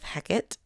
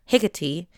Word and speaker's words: Hecate: Meanwhile, Hades is in the car with Hecate. Fun fact, I googled it. I googled the pronunciation, and the Greek pronunciation is Hecate, or Hecate, and the Shakespearean pronunciation is Hecate.